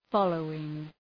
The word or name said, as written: following